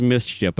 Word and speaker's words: MSCHF: And basically, a company, I don't have the name here, but they basically take Nike, the company is called, like, I think it's pronounced Mischief, but it's spelled M-S-C-H-F, and apparently they've done this before for other artists.